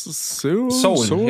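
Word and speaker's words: Seoul: Spricht man Soul aus? – Soul, ja. – Ja, ne? Soul.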